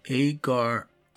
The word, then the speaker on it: agar: A-G-A-R. Let's see. How am I supposed to pronounce this? Agar art. So survey says agar. Okay, right on.